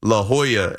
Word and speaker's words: La Jolla: You know, it's pronounced La Jolla.